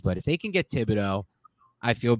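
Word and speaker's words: Thibodeaux: And hopefully they can, like, the Giants can finagle their way into a spot where they can get Thibodeau. All right, is that how you pronounce his name? – Yeah, Kayvon Thibodeau, the Oregon pass rusher.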